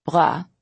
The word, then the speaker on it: bras: The French word bras, with a silent S, means arm.